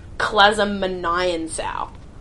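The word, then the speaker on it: Clazmonian Sow: So the Klasmonian Sow is actually not pronounced the way that Rick wrote it in the book. It's pronounced Klasmonian Sow.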